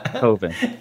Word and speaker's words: Coven: Is it Coven or is it Coven? – Yeah. Coven. And Coven sounds like oven. That was his explanation why it's Coven.